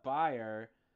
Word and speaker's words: Bayer: Bayer, actually. I know his last name is pronounced Bayer now because he's related to Vanessa Bayer, which I didn't realize until recently.